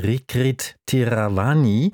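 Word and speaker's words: Rirkrit Tiravanija: Dort hat der thailändische Künstler Rikrit Theeravani, richtig ausgesprochen, ja, eine große Solo-Ausstellung.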